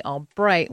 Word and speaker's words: Albrecht: My company is actually a name after her, but I spelled it Albrecht, which is how my dad pronounces our name, but I spelled the company Albright, which is the phonetic pronunciation that my grandmother used.